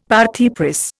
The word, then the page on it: parti pris: Indian